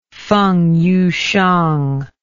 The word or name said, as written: fengyuhsiang